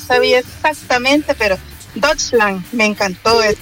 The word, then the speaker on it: Deutschland: Pero si sí le interesa, se pronuncia Deutschland. Deutschland.